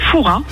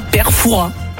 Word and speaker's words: Fouras: Eh bien écoutez, on dit Fouras. On ne prononce pas le S. – Et donc on dit bien Père Fouras. – Voilà, tout à fait.